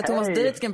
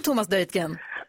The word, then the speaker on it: Thomas Deutgen: Jag har aldrig hört någon säga Thomas Deutgen på danska. God morgon Thomas Deutgen. – Ja, men god morgon. Det var ju faktiskt, jag tror att med fördel uttalar man mitt efternamn på danska.